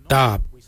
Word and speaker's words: dhaab: Today we will look at, for knife we say dab. Dab is spelled D-H-A-A-B.